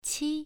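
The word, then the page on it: qi: tone 1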